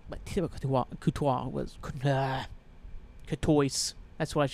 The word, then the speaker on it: Courtois: And Thibaut Courtois, the Belgian keeper. I don't think I said his name. No, it's Courtois.